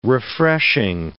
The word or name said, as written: refreshing